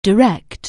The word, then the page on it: direct: anglais